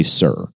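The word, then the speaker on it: Cyr: It's C-Y-R. Is that Sir? Seer? I'm going to say Sir. – I'd say Sir, yeah.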